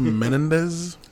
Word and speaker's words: Menendez: it's pronounced Menendez.